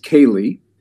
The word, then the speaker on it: Callie: Well, so that one looks like Callie, pronounced Kaylee.